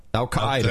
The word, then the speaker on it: Al-Qaeda: He said it's not Al-Qaeda. That's completely wrong. It's Al-Qaeda. Al-Qaeda. – Al-Qaeda. – Do it. Al-Qaeda.